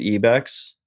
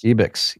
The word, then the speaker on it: Ibex: Um, there like so like i'm wearing the ibex hat actually i found out like we're supposed to be pronouncing it ebex but like i've been chilling we've been chilling with the ibex